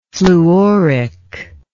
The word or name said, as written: fluoric